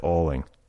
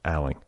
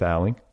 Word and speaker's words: Alling: You look at her name, and I think you want to say, if you're anything like me, Meredith Alling. But I was informed shortly after her arrival here last week that her name is actually pronounced Alling. It's Meredith Alling.